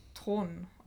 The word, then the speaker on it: Theron: Ähm, aber eigentlich wird es äh, wohl in Afrikaans Thron ausgesprochen. Ich kann es aber auch nicht ganz. Es ist ein Thron.